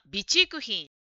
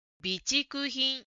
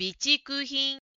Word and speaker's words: 備蓄品: 1 番の答えの文にある備蓄品という言葉のイントネーションについてですが、ジムさんは備蓄品と言っていましたが、これを自然に発音すると備蓄品になりますので、イントネーションに気をつけましょう。備蓄品、備蓄品です。